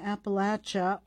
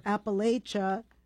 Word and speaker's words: Appalachia: And before that, we heard Rising Appalachia, or Appalachia, depending on where you're from in the United States, how you say it, a new album, Trade My Troubles.